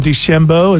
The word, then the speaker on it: DeChambeau: Bryson DeChambeau, is that how you say his name? – That's right.